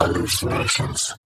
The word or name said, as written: Hallucinations